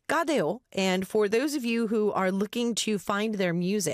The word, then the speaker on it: Cadejo: Gadeo is how you pronounce their name, but for those of you who are reading this in English, we are going to butcher this if we just saw the English spelling of the way that they write out their team name. So C-A-D-E-J-O.